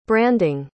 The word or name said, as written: branding